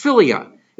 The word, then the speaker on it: philia: Pronounced philia.